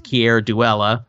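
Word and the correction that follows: Keir Dullea: but I don't know how you really say it. – I think it's Kier Delay. – Kier Delay. Okay.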